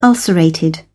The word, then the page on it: ulcerated: British English